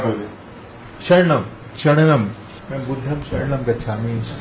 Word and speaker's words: saranam: Saranam in Thai. Yeah. But you pronounce as a Charanam. – Charanam. – Charanam. We use the NG and use M. Yeah.